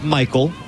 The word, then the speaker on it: Maikol: Michael Hernandez, who spells his name M-A-I-K-O-L, but pronounced just like Michael.